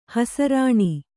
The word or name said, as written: ಹೆಸರಾನು